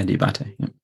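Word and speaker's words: Diabaté: Is that how you say his name? – Yeah, Diabate.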